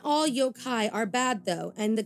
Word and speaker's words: yokai: Now, I can't remember if we dug into it, but the Japanese word for a monster is yokai. And I hope, again, I'm saying that right. – Yes, it is. You're saying it right because Monstrum talks a lot about yokai.